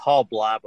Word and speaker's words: Halbleib: It's been butchered for the last 51 years of my life, but it's Halbleib with a long I.